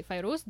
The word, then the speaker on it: Fairuz: And I read a lot about the singer Fairuz.